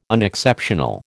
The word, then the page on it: unexceptional: American English